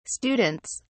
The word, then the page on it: students: British English